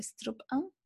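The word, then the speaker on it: strap-on: ¿Cómo se pronuncia eso? Strap on.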